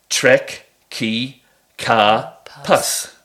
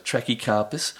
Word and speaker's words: Trachycarpus: So, in order to make those words in the correct manner, you break it down into syllables. So, trek, ki, ka, pus. – Yes. – Trek, ki, ka, pus. And that's how you say it. So it's trachycarpus, okay.